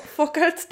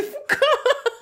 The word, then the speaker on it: Foucault: Fokalt czyta się Foucault.